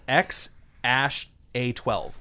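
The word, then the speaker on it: X Æ A-12: It's just X, the letter X, and then AE, which is pronounced Ash. So it's X-Ash-12. Then A-12. – That's not any better. – So it's X-Ash-A-12.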